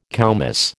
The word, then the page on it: koumiss: American English